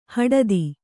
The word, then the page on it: ಹಟಾತ್ತು: haṭāttu